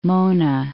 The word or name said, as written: Mona Passage